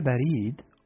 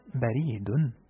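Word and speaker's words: berid (بريد): Se pronuncia Berid o Baridun también.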